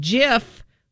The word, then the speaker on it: GIF: He played a short video on the big screen that said it's pronounced GIF with a J, not a G.